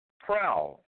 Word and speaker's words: prow: Or is it brow? Let me try to pronounce it correctly. Prow. P-R-O-W.